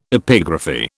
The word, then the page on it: epigraphy: British English